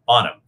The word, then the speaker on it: Bonham: And Daniel, do you say it Bonner? Is that how you say it? – Bonham. – Bonham. I kept looking at it. The way I wrote this, it's like I have an ER on the end of it, which is my problem.